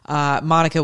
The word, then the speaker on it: Monica: Monica with a C, M-O-N-I-C-A, so it's Monica. It is not Monica. Nothing wrong with Monica. It just doesn't happen to be how you pronounce my name.